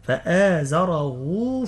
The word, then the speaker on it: فآزره: هنا مضمومة نقول فآزره فاستغلظ، كيف ننطقها؟ ننطقها هاء بعدها واو، هي مرسومة في المصحف هاء عليها ضمة، لكن في النطق هاء واو، ننطقها هاء واو، فآزره فاستغلظ.